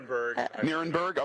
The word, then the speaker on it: Nirenberg: Am I saying that right? – Nirenberg. Okay. – All right. Close enough.